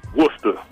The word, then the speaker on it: Worcester: We pronounce it Worcester. – Worcester. Worcester, Mass. – Like W-O-O-S-T-A, Worcester.